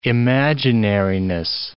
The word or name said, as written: imaginariness